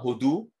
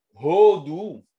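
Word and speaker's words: Odu (הודו): Odu, quand on met le dagesh, l'accent tonique à la fin, Odu, alors c'est l'Inde. Mais Hodu, c'est louer Hachem.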